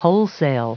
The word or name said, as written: wholesale